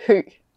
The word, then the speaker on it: Hø: You will hear her name as it should be pronounced in Danish. – Okay. My name is Astrid Hø.